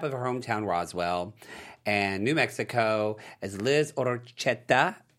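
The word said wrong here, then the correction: Ortecho: Hashtag white. – Orteco. – That is me. Yeah. – I actually wrote it out phonetically so I could pronounce it Orteco.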